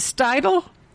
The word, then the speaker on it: Steidel: Is it Steidel? James? – You got it. – Oh, good. – The E is silent.